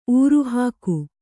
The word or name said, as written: ಊರುಹಾಕು